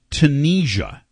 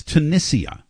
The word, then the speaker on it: Tunisia: And a lot of people call it Tunisia, okay, which is not right. It's Tunisia is really the correct pronunciation.